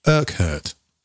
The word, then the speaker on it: Urquhart: But there was a guy called John Urquhart. That is how you pronounce it. I found out. Urquhart. Urquhart.